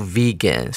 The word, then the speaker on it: vegan: 然后在近年来有一个英文单词非常的流行，那就是 vegan。同学特别注意哦，v e g a n，它的母音一是念成。 e 啊，两个 e 的音，vegan，v e g a n，它就是 vegetarian 素食者的一个比较简单的称呼。比方说啊，我们可以这样子造句：Let's go vegan。